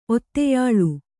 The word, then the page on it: ಒತ್ತೆಯಾಳು: otteyāḷu